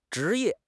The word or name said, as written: zhi2 ye4